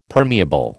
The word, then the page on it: permeable: American English